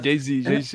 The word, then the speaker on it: Jay-Z: Jay-Z, Jay-Z.